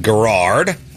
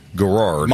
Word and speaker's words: Garrard: Ryan Garrard at plus 3,500. – He's kind of been lurking this year. Yeah, Ryan Garrard's been lurking. – Is it Garrard or Garrard? – Ryan Garrard. – Garrard.